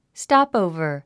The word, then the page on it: stopover: stɑ́pòuvə r; ストップオーバー